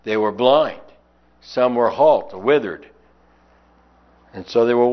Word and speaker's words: healt: It comes from the Anglo-Saxon word halt, which is H-E-A-L-T, and it's pronounced that way, and it's translated that way because a person who is lame has to stop frequently due to their lameness.